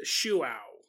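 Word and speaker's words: João: His name is spelt J-O-A-O, but I think it's pronounced Shuao.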